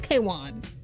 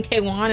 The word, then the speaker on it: KWN: Queen, well, the artist, her name is spelled K-W-N. – Right. – Her name is not Queen. Her name is K-Wan. I did not even know that. – But she pronounces it Queen. – No. They were saying K-Wan, and I was like, why did they say her name wrong?